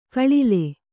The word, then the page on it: ಗಳಲೆ: gaḷale